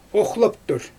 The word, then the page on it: oxlaptur: Xyzyl